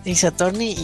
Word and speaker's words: Ace Attorney: ¿cómo se pronuncia? ¿Ace qué? – Ace Attorney.